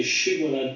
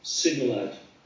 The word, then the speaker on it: Shibboleth: They couldn't say Shibboleth, but say Sibboleth.